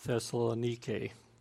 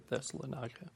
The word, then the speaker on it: Thessalonike: And from what I understand, it's really Thessalonike, if you're pronouncing it correctly, but that's too hard, so I'll keep saying Thessalonica.